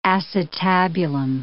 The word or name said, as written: acetabulum